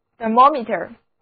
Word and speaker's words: thermometer: Meter, M-E-T-E-R, 这个单词意思是仪表，加在词根 T-H-E-R-M-O 的后面就得到单词 thermometer, thermometer, T-H-E-R-M-O-M-E-T-E-R, 字母 E-R 读作短的 a, O 读作 O, 第二个音节是重读音节。